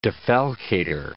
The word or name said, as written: defalcator